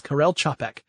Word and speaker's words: Karel Čapek: Karel Čapek. Yeah, because his last name is spelled C-A-P-E-K, and it includes symbols that are not in the English alphabet. – Right. – Above, like, squiggly lines and little UFOs and things. He wrote R-U-R, also known as Rossum's Universal Robots.